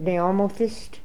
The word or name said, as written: Neomothist